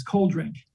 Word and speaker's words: cold drink: And you don't even pronounce the D, it's cold drink.